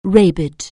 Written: rabid

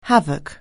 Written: havoc

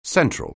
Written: central

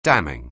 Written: damning

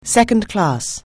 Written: second-class

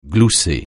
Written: glousser

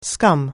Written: scum

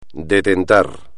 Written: detentar

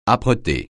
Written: âpreté

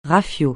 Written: rafiot

